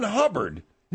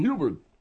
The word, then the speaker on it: Hubbard: Ron Hubbard. – You call him Hubert. – Yeah, that's right, bud. They'll put a Scientology goon on you because you mispronounced the guy's name.